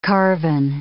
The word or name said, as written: carven